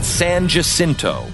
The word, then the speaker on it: San Jacinto: Two armies camped at San Jacinto. Yes, that's the right way to say it in Texas.